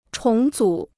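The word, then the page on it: 重要: zhòng yào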